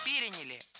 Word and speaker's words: переняли: Мы успешно эту традицию переняли, и Новый год превратился в главное зимнее светское событие. – Переняли. И это тоже ошибка. Правильный вариант – переняли. Ударение ставим на третий слог.